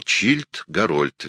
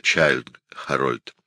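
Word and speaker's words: Childe Harold: Пушкин и в статьях до конца писал «Чильд Гарольд» вместо «Чайльд Харольд».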